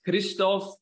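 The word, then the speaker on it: Hristov: It's actually pronounced as Christoph, but I never expect anybody to get it correctly, because unless you speak my Bulgarian, where I'm originally from, or some sort of a Slavic language based on the Cyrillic alphabet, you would never know how to pronounce it.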